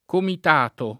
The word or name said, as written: comitato